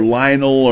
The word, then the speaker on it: Lionel: Is it not Lionel or Lionel or whatever it is? – Lionel. – Lionel, okay.